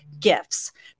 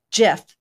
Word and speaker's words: GIF: Some folks will pronounce it with a hard G sound, while others prefer the soft G sound, as in GIF. Now, the original creators of the GIF file format pronounce it like GIF, like the GIF peanut butter you have at home.